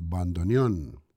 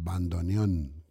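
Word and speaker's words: bandoneón: Per Arne Gløbjørgen in bandoneon. That's the right pronunciation, bandoneon, with an accent at the end, bandoneon.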